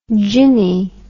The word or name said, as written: jinny